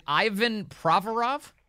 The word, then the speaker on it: Ivan Provorov: Is it Ivan Provorov? – Yeah, you said it right.